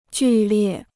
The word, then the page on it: 剧烈: jù liè